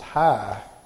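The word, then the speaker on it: hi (חי): The word chai, pronounced hi, in Hebrew means life.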